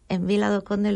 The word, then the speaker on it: Vila do Conde: Que además, como están en Vila do Conde, lo he dicho bien, ahora sí.